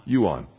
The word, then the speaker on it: Yuan: How do you say that name? Yuan. That's just like it sounds.